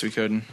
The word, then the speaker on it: Suikoden: Yeah, Suikoden. How do you say it? – Yeah, it's Suikoden.